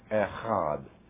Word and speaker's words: Echad: Echad is the way it's pronounced if you're going to pronounce it correctly in Hebrew.